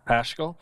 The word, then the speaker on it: Paschal: Um, I honestly, out of the whole list, I'm very excited to see uh, Pascal or Paschal. I'm not exactly sure how to pronounce Paschal. – Paschal.